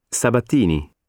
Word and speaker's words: Sabatini: Sabatini. – How do you spell it? – S-A-B-A-T-I-N-I.